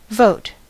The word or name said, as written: vote